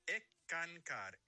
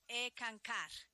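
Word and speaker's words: Eckankar: Y hablábamos de que eres miembro de ENCCAR, ¿no? – Se pronuncia ECANCAR. S-K-A-N-K-A-R. Significa ECANCAR. Colaborador. Significa colaborador con Dios. – Perfecto. ECANCAR.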